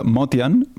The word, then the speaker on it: Motian: Paul Motion, en vera, se pronuncia Motian perquè és descendent de gent d'Armènia,